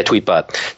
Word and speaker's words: Tweetbot: Yeah, Tweetbot.